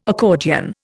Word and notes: accordion: Indian

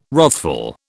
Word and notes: wrathful: British English

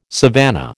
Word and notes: savannah: American English

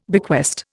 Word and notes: bequest: Indian

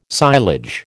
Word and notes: silage: American English

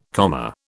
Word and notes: comma: British English

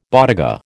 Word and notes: bodega: American English

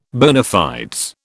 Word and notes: bona fides: British English

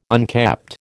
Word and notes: unkept: American English

